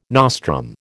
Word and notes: nostrum: American English

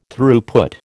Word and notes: Throughput: American English

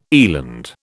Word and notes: eland: British English

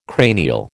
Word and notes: cranial: American English

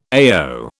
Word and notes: AO: British English

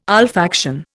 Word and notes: olfaction: Indian

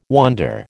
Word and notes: wonder: American English

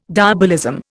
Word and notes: diabolism: Indian

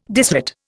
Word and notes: disrate: Indian

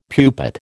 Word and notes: pupate: American English

